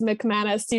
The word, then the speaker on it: McManus: It's Lily with one L and then it's McManus. You just got to sound it out like mk.